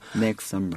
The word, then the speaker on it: next summer: 그러니까 어떻게 발음해요? – Next summer. – 그렇죠. T를 살짝 죽여주세요.